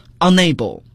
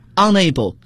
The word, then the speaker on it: unable: 大家注意它的发音，不要读 unable 而读 unable。它有一个很重要的用法， be unable to do something，不能够做某事。